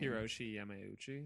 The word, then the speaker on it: Hiroshi Yamauchi: Hiroshi Yamauchi.